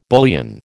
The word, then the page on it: bullion: American English